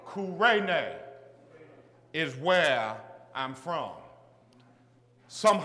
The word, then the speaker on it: Cyrene: Those of European and Western descent pronounce the name of my country as Cyrene. However, it's really pronounced Kurene.